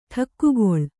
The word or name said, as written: ಠಕ್ಕುಗೊಳ್